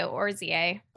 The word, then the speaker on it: oryzae: How should I say that one? Aspergillus? – I say oryzae.